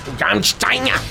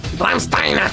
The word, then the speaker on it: Rammstein: Rammstein. Rammstein.